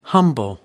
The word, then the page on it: humble: American English